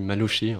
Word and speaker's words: Malucci: C'est un roman qui parle d'un certain Bailly, d'un certain Malucci aussi, ou Malucci, je ne sais pas comment on prononce. – Oui, Malucci. Enfin, Malucci, et qui est plus ou moins votre grand-père, c'est un petit peu votre histoire familiale.